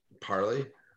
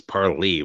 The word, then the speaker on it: parlay: Uh, it's par- parley. – Yeah, parley. It's weird, right? It doesn't even sound right, but it's parley with the long e at the end.